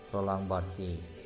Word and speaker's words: Roland Barthes: baik yang terkait dengan Roland Barthes ada pronouncingnya Roland Barthes ya Roland Barthes tapi kalau anda mau pronouncing ala Prancisnya sih uh, anda bilangnya Roland Barthes Roland Barthes nah gitu uh,